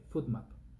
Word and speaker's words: FODMAP: Se escribe con F-O-D-M-A-P, FoodMap.